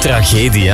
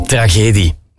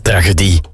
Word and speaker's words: tragedie: tragedie, hè? Zo spreek je dat uit. Ik had tragedie gezegd. Jan uit Merkspas vroeg zich al af of hij het al heel zijn leven fout uitspreekt en ik het juist heb. Nee, tragedie, niet tragedie.